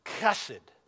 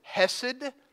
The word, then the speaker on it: chesed: Now I put the guttural in there. You know why? Because that's the way it's pronounced in Hebrew. And we often refer to it as hesed, but it's not. It has a C in front of it. It's chesed.